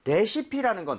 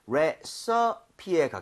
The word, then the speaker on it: 레시피: 익숙한데 우리가 레시피라고 이제 외래어 표기는 하지만 실제로 발음할 때는 레서피에 가깝게 발음을 좀 했으면 좋겠거든요.